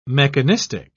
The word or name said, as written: mechanistic